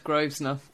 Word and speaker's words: Grosvenor: It's like Grosvenor, which isn't said Grosvenor pronounced.